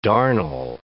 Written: darnel

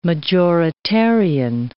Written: majoritarian

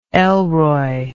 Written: elroy

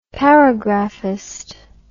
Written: paragraphist

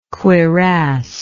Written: cuirassed

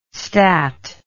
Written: stat

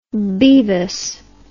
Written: Bevis

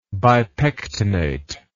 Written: bipectinate

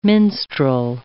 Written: minstrel

